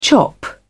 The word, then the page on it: chop: British English